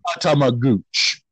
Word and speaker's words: Tatamagouche: Um, this letter, this word is pronounced is spelled T A T A M A G O U C H E, like Tamagooch. But I'm pretty sure that's not it.